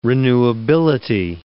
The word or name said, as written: renewability